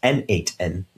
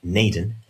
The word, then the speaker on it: n8n: That's pronounced N8N, not Naden, as I had incorrectly assumed.